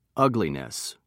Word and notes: ugliness: British English

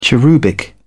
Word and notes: cherubic: British English

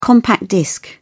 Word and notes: compact disc: British English

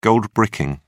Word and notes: goldbricking: British English

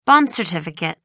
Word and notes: bond certificate: American English

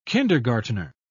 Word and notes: kindergartner: British English

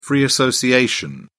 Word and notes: free association: British English

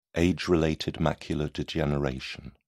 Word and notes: age-related macular degeneration: British English

